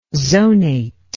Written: zonate

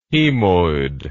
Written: hemoid

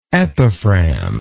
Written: epiphragmal

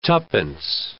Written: tuppence

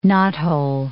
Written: knothole

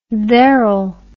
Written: therell

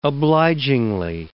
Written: obligingly

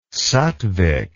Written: sattvic

